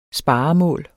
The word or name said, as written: sparemål